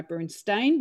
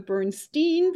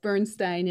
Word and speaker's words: Bernstein: And I'm going to stick with my Bernstein because I know that's the right way to say it. Many Americans go with the Bernstein because we'll turn it around, but Bernstein it is.